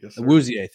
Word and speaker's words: Awuzie: Okay, speaking of support, former Colorado player, Chidobe Awuzie, I believe is how you say it. Awuzie, thank you.